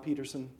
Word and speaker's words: Petersen: Tom Peterson, and it's spelled P-E-T-E-R-S-E-N.